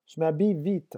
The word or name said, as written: je m'habille